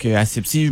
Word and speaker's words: asepsie: On appelle ça l'asepsie. Je le dis bien? – Oui, exactement. Donc, asepsie, ben, le mot le dit un petit peu, là, ça vient du verbe aseptiser.